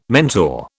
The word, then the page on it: mentor: British English